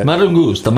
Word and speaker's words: Mangusta: The Mangusta. Mangusta. Yeah. Say it right. Say it's Mangusta. Mangusta.